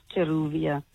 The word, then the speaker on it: Jeruvia: Usually it's like Javina or Jeruva, but sometimes they don't like pronounce it right. It's Jeruvia.